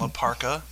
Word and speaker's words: L.A. Park: One pronounces his name as L.A. Park and the other one La Parka.